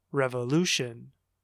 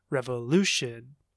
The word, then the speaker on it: revolution: All right, and the last long word is revolution, revolution. Do you hear the stress? It should be on the third syllable, syllable three, revolution, revolution, revolution.